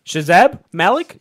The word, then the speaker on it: Shahzeb Malik: I'm here with Shazab Malik? – Yes.